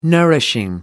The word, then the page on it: nourishing: American English